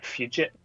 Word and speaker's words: Fugit: I think Fugit, like fugitive. – But is that a name? Fugit?